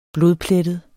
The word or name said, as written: blodplettet